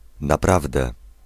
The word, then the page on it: naprawdę: [n̪aˈpravd̪ɛ]